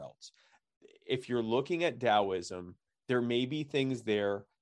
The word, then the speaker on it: Taoism: Another thing that you can look into is something called Taoism, and it's spelled T-A-O, not D-A-O, T-A-O-I-S-M.